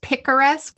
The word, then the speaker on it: picaresque: I had never known what a picturesque plot was. – I think it's picaresque. – I didn't know how to pronounce it either, apparently.